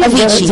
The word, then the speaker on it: Avicii: Avicii, yes.